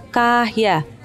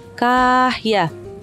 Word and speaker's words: Kaya: Ditulis namanya Kaya. K-A-Y-A. Tapi cara bacanya Kahya. Kahya.